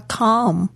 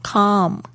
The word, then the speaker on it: calm: calm And that we respect their decision 我们想要表示想要表达的就是 We want to show 是想要表示什么样子呢 We are calm Calm C-A-L-M 特别注意一下这 L 没有发音 Calm And that we respect their decision Respect 也是我非常喜欢的一个单词 R-E-S-P-E-C-T